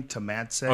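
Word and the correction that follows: Tomasz Adamek: Oh, Thomas Adamek? – Adamek, yeah.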